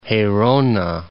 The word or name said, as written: Gerona